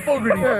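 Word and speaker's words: Fogerty: Is it Fogarty? Fogarty? – Close. Fogarty. Yeah. – Fogarty. – You're pretty close.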